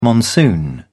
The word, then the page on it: monsoon: British English